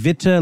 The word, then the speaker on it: Witte: Witte? – I think so. Witte, yeah. Witte. I think Witte like bitter, like please. Yeah. Auf Deutsch.